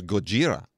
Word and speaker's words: Gojira: Yeah, the actual name in Japan is Gojira. – Oh, G-O-J-I-R-A. But, you know, Americans being as slovenly as we are, we pronounce it as Godzilla, and there we are.